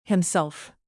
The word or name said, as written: himself